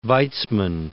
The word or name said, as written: Weizmann